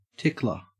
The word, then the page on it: tickler: Australian English